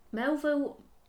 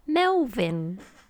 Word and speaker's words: Melville: So, Melville Macken... I looked at this the other... last night. – Melvin? – No, it's Melville. – Oh, Melville.